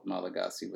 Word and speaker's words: Malagasy: Like, I have no idea why they decided with Malagasy, which is a five-vowel system, they decided to write E at the end of a word as a Y, like it is in English. – Oh, no. – Yeah, that's why it's spelled Malagasy with a Y.